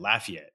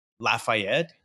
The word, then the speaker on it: Lafayette: So, like, talking about that, it's so my wife's from, and again, it's they pronounce it Lafayette, right? Like, but when I met her, her family's like, oh, you know, from Louisiana, Lafayette, blah, blah. I was like, you mean Lafayette? Because in French, it'd be like Lafayette.